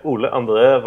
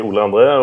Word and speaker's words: Ole André: Yeah, Ole André Vadle That's how I say my name. It's really complicated, so just call me Ole André or Ole.